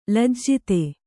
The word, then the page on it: ಲಜ್ಜಿತ: lajjita